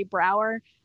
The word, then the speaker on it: Brouwer: That, if you want to follow me, I'm at Brie Brouwer, B-R-O-U-W-E-R, because it's a Dutch name and a lot of people don't know how to spell it.